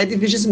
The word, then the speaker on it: Edviges: Mas Edviges, então, é Edviges mesmo, né? A pronúncia correta aí. – É, mulher.